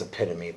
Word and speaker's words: epitome: That's epitome, by the way. – I know. – Gotcha. I'm borrowing my Michael. – It's like my dad who always puts the emphasis on the wrong syllable.